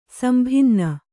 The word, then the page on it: ಸಂಭಿನ್ನ: sambhinna